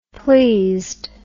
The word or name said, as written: pleased